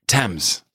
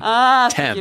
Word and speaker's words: Thames: There are silent letters in it, and you know, yes, Thames. It should almost. How would you spell Thames? – T-E-M-M-S.